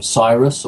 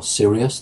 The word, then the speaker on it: Sirius: These are, of course, from Sirius or Sirius, depending on your pronunciation, the dog star.